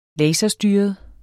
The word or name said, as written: laserstyret